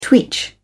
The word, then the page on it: twitch: British English